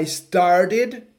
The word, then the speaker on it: started: I started, temos que ter cuidado com essa pronúncia. I started. Cristina fala Started. I started. I started. Temos diferentes pronúncias, mas temos que escutar as duas sílabas.